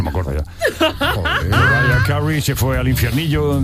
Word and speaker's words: Mariah Carey: María Carey, yo te lo digo en castellano y tú en cómo se dice bien. María Carey. – Mariah Carey.